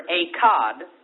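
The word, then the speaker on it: Echad (אחד): The word one in Hebrew is spelled E-C-H-A-D, Echad.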